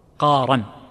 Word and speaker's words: قارا: الاحتمال الأول بالتسهيل للراء قارا كما نطقها القارئ جزاه الله خير وهذا صحيح